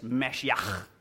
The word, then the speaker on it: Mashiach: Messiah is Hebrew. Or to say it better, is Mashiach. Okay, that's how you say it in Hebrew.